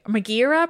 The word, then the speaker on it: Megara: These three women, their names were Alecto, Megara, Megara, I should say. Megara, probably is the pronunciation, and Tisiphone.